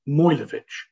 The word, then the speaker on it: Mogilevich: is in fact spelt Mogilevich, but it's pronounced Mojlevich.